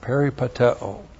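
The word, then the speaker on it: peripateo (περιπατέω): P-E-R-I-P-A-T-E long O. Peripateo.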